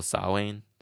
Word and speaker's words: Samhain: And they put on bad received shows like, what's it called, Samhain? – Yes, it was. – I said it right.